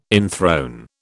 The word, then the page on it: enthrone: British English